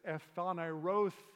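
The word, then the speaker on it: Ephanerothe (ἐφανερώθη): Each line ends in the sound, the syllable the. It goes like this: Ephanerothe, he appeared.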